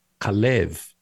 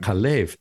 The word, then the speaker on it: Kalev: And so the proper pronunciation in the Torah, according to the Masoretic pointing, is Kalev. – Right. – Kalev, the accent on the second syllable. – Right. – Kalev, where I just did a quick skim and it looks like it's consistently the second syllable that right.